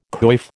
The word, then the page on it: coif: American English